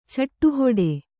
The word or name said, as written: ಚಿಟ್ಟುಹಿಡಿ